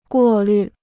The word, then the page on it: 过滤: guò lǜ